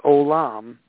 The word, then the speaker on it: Olam: T-I-K-K-U-N. There's a first word, and it means repair, repair. And the second word is Olam, O-L-A-M. O-L-A-M. So I'll repeat, Tikkun, T-I-K-K-U-N, and the second word is Olam, O-L-A-M. So together, they are pronounced Tikkun Olam.